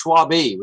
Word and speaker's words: Schwabe: You no, it's it's not like Charles Schwab. It, it's Schwabe. We got an e on the end.